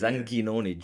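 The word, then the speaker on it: Anghinoni: That's a difficult one. It's Anginoni, G-H.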